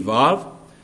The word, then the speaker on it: waw: And the word WAW, it means and. Some pronounce it with a V, Vav. But I'm just sticking to what I heard when I went to seminary, the textbook that I used in studying Hebrew.